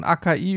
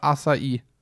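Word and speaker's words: Açaí: die, Aus, die Aussprache variiert hierzulande von AKI über Asai bis hin zur korrekten Art und Weise Asai. Achai wo, ist natürlich wo, komplett zeig, falsch.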